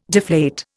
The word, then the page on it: deflate: Indian